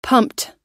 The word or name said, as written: pumped